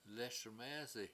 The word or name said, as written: lES1mEEsi